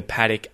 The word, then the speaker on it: paddock: Paddock is spelt P-A-D-D-O-C-K. However, I say paddock. Okay, notice the schwa in there.